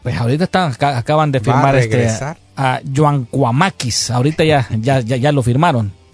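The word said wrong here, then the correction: Giakoumakis: Es Giacomaquis. – Ah, Giacomaquis. – Sí, así normal. Giacomaquis.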